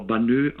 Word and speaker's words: Banneux: Ja, es wird Banneux geschrieben, aber Banneux ausgesprochen, das X hört man nicht.